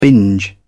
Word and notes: binge: British English

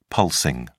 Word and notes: pulsing: British English

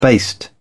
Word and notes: baste: British English

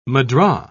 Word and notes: mudra: British English

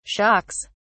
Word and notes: shocks: British English

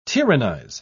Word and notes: tyrannise: British English

